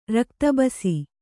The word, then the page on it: ರಕ್ತಬಸಿ: Kannada